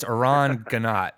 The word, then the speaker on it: Aran Ganot: You know, there's Aran Ganot, you could have said, but it's Aran Ganot is how you want to pronounce it. Is that correct? – That's correct. Okay. It's not French, although you'd be forgiven for thinking so.